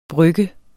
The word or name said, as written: brygger